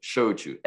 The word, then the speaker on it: shochu: And first of all, Shochu, S-H-O-C-H-U, and you pronounce it perfectly.